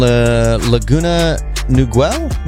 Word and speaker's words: Laguna Niguel: And then there's Benny in Laguna Niguel.